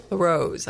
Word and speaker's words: La Rose: In La Rosa. So, La Rose, I guess it pronounced. No E.